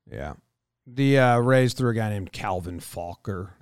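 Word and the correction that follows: Faucher: Oh, it's not Calvin Fucker. It's Calvin Fauché.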